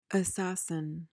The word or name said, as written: assassin